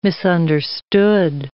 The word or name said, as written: misunderstood